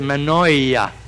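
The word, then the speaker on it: Mannoia: And now, when I say my name, I say Manoia.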